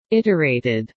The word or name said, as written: iterated